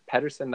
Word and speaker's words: Pedersen: I mean, I believe Peder- Pedersen. That's how you say it, right? Yeah. Yeah, he he had something like what was it like five or six points in the first five games, I believe.